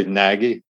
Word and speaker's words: Nagy: G-N-A-G-Y? – A-N-A-G-Y. Nagy. – Okay. Well, his was G-N-A-G-Y. So. – G-N-A-G-N-A-G-Y. – Yeah, well, I say Nagy, silent G.